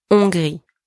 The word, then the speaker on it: Hongrie: And don't pronounce the H here. Hongrie.